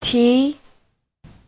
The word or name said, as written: ti2